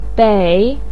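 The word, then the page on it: bei3: tone 3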